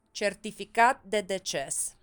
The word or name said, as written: certificat de deces